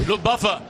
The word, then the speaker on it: Luftwaffe: Sigue intentándolo, lo está haciendo por arriba, la Luftwaffe. Bueno, dirá cómo se pronuncia eso bien. – Luftwaffe.